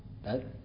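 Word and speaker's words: Tết: It's called Tet in Vietnamese, right? Is that accurate? Tet? Do I say that right? Tet? Okay.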